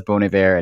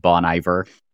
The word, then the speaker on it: Bon Iver: And if you have never actually said that out loud, it took me a long time to realize it was Bon Iver and not Bon Iver,